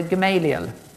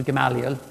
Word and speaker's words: Gamaliel: And he trained under Gamaliel, or Gamaliel, whichever pronunciation you prefer.